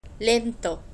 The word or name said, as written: lento